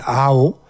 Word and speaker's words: Aho: Alors, c'est un compositeur finlandais que nous allons écouter, Monsieur Kalevi Aho. Aho, ça s'écrit A-H-O, c'est très simple, pour une fois, un nom finlandais qu'on peut prononcer.